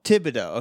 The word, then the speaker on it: Thibodeau: It's a silent H. It's Thibodeau, but I don't know if you pronounce it or not. I had a teacher named Miss Thibodeau, and you did not pronounce it.